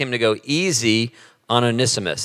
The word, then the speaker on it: Onesimus: Onesimus. Nobody knows how to say it, but yeah, Onesimus. It's about a guy named Onesimus who is a slave, but he's a runaway slave.